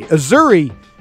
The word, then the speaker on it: Azzurri: Azzurri rhymes with Missouri, okay?